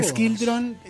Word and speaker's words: Skiltron: Que Skiltron, en realidad se escribe Shiltron, y bueno, se le dio esa...